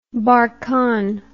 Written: barkhan

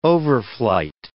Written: overflight

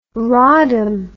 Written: rodham

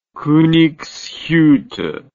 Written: Konigshutte

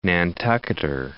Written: Nantucketer

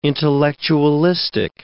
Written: intellectualistic

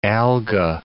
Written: alga

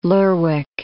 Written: Lerwick

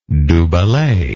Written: du bellay

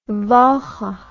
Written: walcha

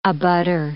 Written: abutter

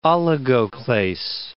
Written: oligoclase

